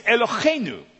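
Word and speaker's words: Eloheinu (אֱלֹהֵינוּ): Now the next word is Eloheinu, okay? Yeah, Eloheinu. Try that. Okay. If there's not spit on the person in front of you, you're not saying it right.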